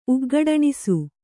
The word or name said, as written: ಉಗ್ಗಡಣಿಸು